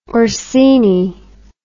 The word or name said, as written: orsini